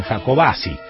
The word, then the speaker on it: Jacobacci: No se pronuncia así. Bueno, yo digo Jacobacci. Ellos dicen Jacobacci. Chubut, buen día, Quique, muy bueno el programa.